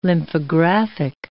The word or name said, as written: lymphographic